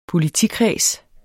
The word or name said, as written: politikreds